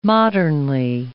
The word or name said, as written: modernly